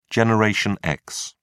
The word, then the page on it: Generation X: British English